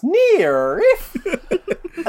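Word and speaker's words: NIIRYF: It's pronounced near if.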